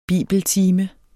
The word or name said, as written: bibeltime